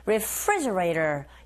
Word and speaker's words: refrigerator: 강세는 Refri, E, 이 부분에 있으니까 Refrigerator. 그리고 T 사운드 있죠, 맨 뒤에. 강모음과 양모음 사이에 자리 잡고 있습니다. 이런 경우에는 T가 아니라 르에 가깝게 들려서 우리에게 들리는 소리가, 즉 원어민의 입에서 나오는 소리가 Refrigerator. Refrigerator 이렇게 발음되니까 그대로 흉내내시면 되겠어요.